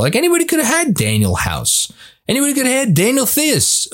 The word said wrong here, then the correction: Theis: or Tice, excuse me. I always mispronounce his name.